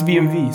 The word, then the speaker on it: BMW: So, Germans pronounce W as V. – Yes. – It's BMV.